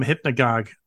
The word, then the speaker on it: Hypnogogue: I want to talk about the new album Hypnagogue. Am I pronouncing it right? I'm pronouncing it right, right? – Yep. Yeah, you okay.